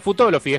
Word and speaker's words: Futolofi: No, es Futolofi, es como Filosofi, pero cambiando por Futolofi, ahí está.